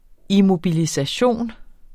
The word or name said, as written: immobilisation